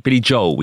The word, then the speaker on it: Billy Joel: Oh, Billy Joel. Billy Joel, we say.